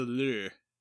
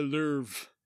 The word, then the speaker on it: Louvre: The Louvre. – That's not how it's pronounced. – The Louvre.